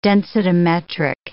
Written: densitometric

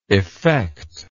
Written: effectible

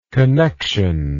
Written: connexional

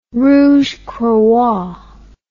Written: rouge croix

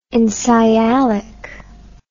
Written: ensialic

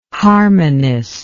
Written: harmonist

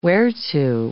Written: whereto

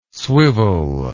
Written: swivel

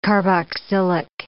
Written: carboxylic

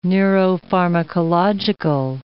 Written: neuropharmacological

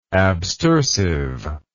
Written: abstersive

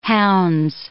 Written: hounds

